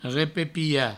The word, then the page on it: repepiar: repepia; Provençal